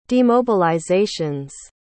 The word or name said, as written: demobilizations